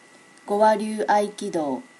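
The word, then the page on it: gowaryuaikido: native Japanese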